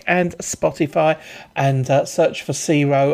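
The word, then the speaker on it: C-RHO: So it's C-R-H-O, pronounced Ciro.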